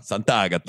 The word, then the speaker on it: Sant'Agata: Sant'Agata.